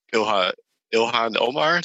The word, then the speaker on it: Ilhan Omar: Shout outs to Ilhan Omar. Did I say her name right? – Yeah. She was there too.